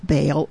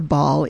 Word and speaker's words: Baal: But he took, the Lord said, take your father's bull and a second bull and pull down the altar of Baal, or Baal, if you want to pronounce it that way, which belongs to your father, and cut down the Asherah.